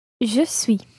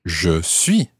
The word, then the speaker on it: Je suis: Je suis. – Je suis. Je suis. – Je suis. – So that's S-U-I-S.